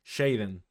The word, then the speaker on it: JXDN: Jaden. Pasa que se escribe X.